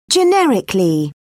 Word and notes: generically: British English

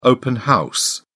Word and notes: open house: British English

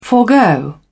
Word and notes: forgo: British English

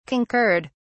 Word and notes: concurred: British English

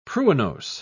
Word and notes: pruinose: British English

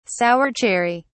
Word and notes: sour cherry: British English

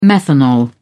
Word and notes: methanol: British English

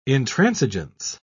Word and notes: intransigence: British English

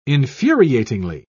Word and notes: infuriatingly: British English

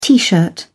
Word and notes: tee shirt: British English

